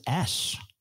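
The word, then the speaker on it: X Æ A-12: Apparently they pronounce it Ash. It's just Ash. It's not too bad. – But it's spelt X. AEA-12. That's not Ash.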